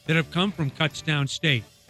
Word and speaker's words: Kutztown: Is it pronounced Kutztown or Cuts. Did you Kutztown? – Kutztown.